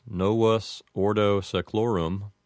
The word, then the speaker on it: novus ordo seclorum: I can't really think of any other way that that would be pronounced. I suppose that if someone said it with an Americanized pronunciation, they might change the sound of the V in novus, and they might say novus, but that's really the only difference I could think of.